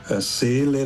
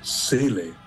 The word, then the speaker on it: Seele: They make a long E, Seele, like Michael Soul, but there's only one E. So I have always to say Michael Seele, only with not Soul, Seele, like with one E.